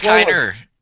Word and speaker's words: Kiner: You know, let's get Klein in. I believe I'm saying his name right. – Kevin Kiner. – Get Kiner in there to work with you.